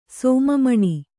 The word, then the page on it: ಸೋಮಮಣಿ: sōma maṇi